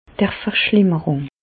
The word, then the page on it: d' Verschlimmerùng: Bas-Rhin; Reichshoffen